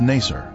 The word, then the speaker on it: Nasir: Its former name in the 19th century was Nasir. This pronunciation is similar to Nicer, a village that the Babylonian Berossus described as being near the Ark site.